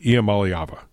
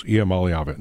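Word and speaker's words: Iamaleava: I have to get it right. Iamaliava. It's seven syllables. Iamaliava.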